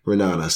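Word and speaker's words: Ra'anana: You push the second A, just keep it silent, and then you can pronounce it. But, Ranana.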